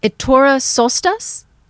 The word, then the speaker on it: Ettore Sottsass: It was started by, say his name correctly, Ettore Sottsass in Milan, Italy, even though he was born in Austria.